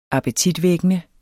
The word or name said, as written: appetitvækkende